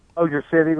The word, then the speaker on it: Bossier City: Back to the phones here in, is it Bossier City, Louisiana? Is that how you pronounce it? – Bossier City, Louisiana.